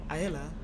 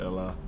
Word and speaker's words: Aella: Yes, Ella. We keep the A silent. – I have been saying Ayla. – Yeah, most people do. So we keep the A silent. – Okay. – We like to pronounce it Ella.